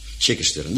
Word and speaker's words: Chichester: Hoe kun je dat netjes uitspreken, zodat iedereen schrijven kan? C-H-I en dan weer C-H-Ester. Chichester, de zuidkust dus van Engeland.